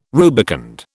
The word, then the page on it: rubicund: British English